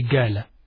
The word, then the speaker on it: qala: You'll find in some Arabic language, in some Arabic colloquial language today, they use the pronunciation ga for the qaf. Instead of saying qala, they say gala, gala.